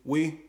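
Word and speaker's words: Oui: How you pronounce this? Kwee? – We.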